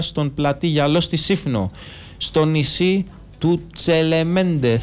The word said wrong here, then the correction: Τσελεμεντέ: θα έχει φαγητό. – Τσελεμεντέ, συγγνώμη. – Τσελεμεντέ, γαλλικά θα το τονίζεις.